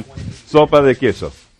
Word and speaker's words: sopa de queso: Sopa de queso. – All right. Yeah. – But no, your pronunciation was fine. It's just the emphasis was a little Italian.